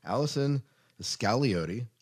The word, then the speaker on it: Allison Scagliotti: We have a very special guest on the show today, Allison Scagliotti. Am I pronouncing oh, that right? On. Okay, great.